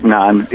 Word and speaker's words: naan: The one that I really liked, or the two that I really liked were Nonstop D.C., but non is spelled N-A-A-N.